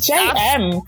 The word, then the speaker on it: Emme: It's JM, because those are my actual initials.